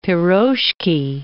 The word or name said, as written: pirozhki